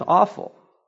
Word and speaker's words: offal: Verse 11, but the bull's hide and all its flesh, with its head and legs, its entrails and offal. Now, maybe you're not familiar with what offal is. That is how you pronounce it.